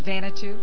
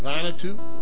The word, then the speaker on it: Vanuatu: What do you think the pronunciation is? – Vanuatu. – Vanuatu. Vanuatu.